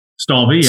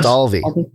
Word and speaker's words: Stalvey: Stalvey. Yes. Stalvey.